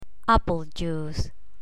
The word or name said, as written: apple juice